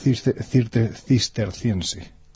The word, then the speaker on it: cisterciense: cisterciense.